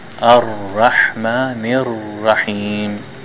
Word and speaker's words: Ar-Rahmanir-Rahim: See the shadda on the ra? I pronounce twice. Ar-Rahmanir-Rahim. Is it thick or thin, the ra? Thick.